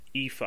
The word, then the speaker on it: Aoife: No, her name is pronounced Aoife. I looked that up.